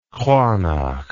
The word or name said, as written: kranach